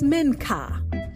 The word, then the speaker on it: Menca: And Minka, that's spelled M-E-N-C-A. His name is Minka.